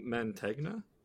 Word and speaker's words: Mantegna: Oh my gosh, it had Joe Pantagna. How do I pronounce that last name? – Joe Mantegna? – Yeah, he was from Criminal Minds.